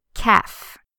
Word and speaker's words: calf: On the other side, we have the calf. Calf. C-A-L-F. The calf is the back part of the leg. So, notice the way we pronounce calf. It's the same as half.